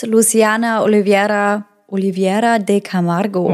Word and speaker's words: Luciana Oliveira de Camargo: Bis Luciana Oliveira de Camargo. – Oh, wow. – Wow. Ja, ich will die richtig aussprechen. Roll ich das R oder nicht? – Das klingt richtig gut.